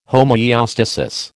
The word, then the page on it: homoeostasis: American English